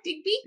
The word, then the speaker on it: Digby: Did I say that right, Digby? – Yes, perfect.